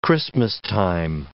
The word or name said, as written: Christmastime